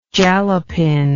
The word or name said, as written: jalapin